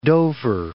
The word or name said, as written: Dover